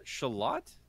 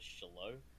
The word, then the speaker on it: shallot: How do you pronounce that? Shallot? – Shallot, yeah. What do you think? It was shallow? – Something else. Shallow? I don't, maybe.